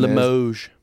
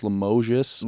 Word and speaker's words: Limoges: With Alex whatever his name Limoges. – Is that what it is? – I think the S is silent, too. It's Limoges.